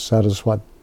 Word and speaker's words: Saraswate: And then because it's two obeisances, then it's changed in the locative case, so it becomes Saraswate with an E in the end, which everybody says Saraswati, but it's not.